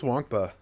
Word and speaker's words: Nwankpa: Xavier Nwankpa? I can't say his name. Do you know how to say his name? – I think it's Wonkpa. – Wonkpa. That would Xavier Wonkpa. That makes more sense.